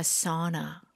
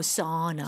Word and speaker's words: sauna: They're basically cooking in a sauna. – 오, 정말로 yeah. 일단은 사우나를 쓴다는 거. 근데 발음이 사우나는 아닐 거잖아요. – No, sauna. – Sauna. Sauna.